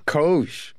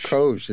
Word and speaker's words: COGE: How do you pronounce the acronym for this commission? – COGE. – COGE. – COGE. It is exactly.